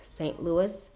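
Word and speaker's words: Saint-Louis: It's Tavares St. Louis. St. Louis is spelled out S-A-I-N-T hyphen L-O-U-I-S.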